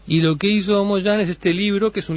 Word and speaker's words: Mo Yan: Bueno, se escribe con Y, ¿no? – Sí, Mo, M-O-Y-A-N. Perfecto. Mo Yan.